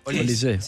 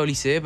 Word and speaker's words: Olise: Olise. – Él lo pronuncia Olise, pero creo que se pronuncia Olis.